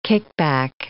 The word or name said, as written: kickback